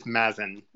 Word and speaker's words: Mazen: And it is Mazen. Very close, though.